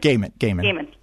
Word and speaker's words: Gaiman: Gaiman. – Am I saying that right? – Gaiman. Yeah.